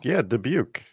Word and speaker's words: Dubuque: Dubuque? – Dubuque.